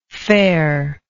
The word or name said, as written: farer